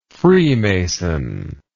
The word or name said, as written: freemasonic